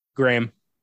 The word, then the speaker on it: Graeme: It's spelled G-R-A-E-M-E. – Graham. – Graham? Okay. Graham Revell.